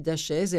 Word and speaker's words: Dachez: Ça s'écrit D-A-C-H-E-Z, autiste Asperger. Elle est nantaise, elle est donc de la ville de Nantes, Julie Dachaise.